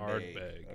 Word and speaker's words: Ardbeg: A-R-T-B-E-I-G. Did I spell that right? – A-R-D. – Oh, D. Ardbeg. – Ardbeg. Okay. Yeah, like a pirate.